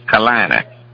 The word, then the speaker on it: Carolina: It's Charlotte, North Carolina. Carolina. It's not nine syllables. It's Carolina. Carolina, three.